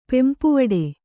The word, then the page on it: ಪೆಂಪುವಡೆ: pempuvaḍe